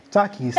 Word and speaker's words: Takis: Takis.